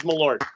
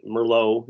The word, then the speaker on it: Malort: It is Malort. You are pronouncing it 100% correctly. What do some people say? Merlot. It's like, no, it's not a wine.